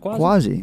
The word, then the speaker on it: Quasi: Casey? Q-U-A-S-I. Obviously. – Quasi. – Is that pronounced Quasi? – Quasi. That's how you spell Quasi.